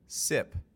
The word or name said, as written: SIP